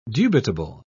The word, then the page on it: dubitable: British English